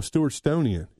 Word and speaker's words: Stewartonian: Another one that I really like is Stewarton. – Oh, Stewartonian. – Yes, I'm sorry. I said it wrong.